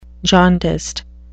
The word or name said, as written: jaundiced